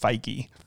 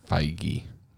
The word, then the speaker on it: Feige: And he's like, by the way, it's pronounced Feige. – Feige.